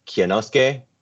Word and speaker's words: Kinosuke: I was checking on that earlier just to make sure, because Kinosuke is like really hard to pronounce. So Felipe Mota, Kinosuke. So you hit the Y like a little bit. Kinosuke, Yamashita, and then Dylan Jabe.